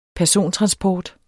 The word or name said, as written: persontransport